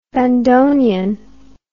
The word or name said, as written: bandonion